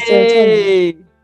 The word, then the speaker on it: serotonin: Is the hippocampus the one that holds the serotonin? Am I even saying it right? – You are. Like, that is serotonin.